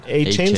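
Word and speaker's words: Achane: Pero en la tercera draftean a un corredor, a Devon A-Chain. – A-Chain se pronuncia.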